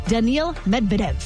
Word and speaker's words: Daniil Medvedev: He is headed to the quarterfinals, defeating 7 seed Daniil Medvedev. I think I said that right. – Yes, you did.